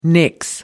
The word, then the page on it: nix: American English